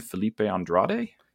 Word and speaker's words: Felipe Andrade: Written by Ram V and art by, I'm going to butcher his name, Felipe Andrade? – You nailed it.